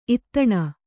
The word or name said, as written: ಇತ್ತಣ